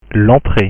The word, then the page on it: l'entree: French